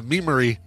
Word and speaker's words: Memery: And they spell it funny. M-E-M-E-R-Y Lane. – Oh, yeah. Yeah. Memory.